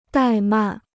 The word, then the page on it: 怠慢: dài màn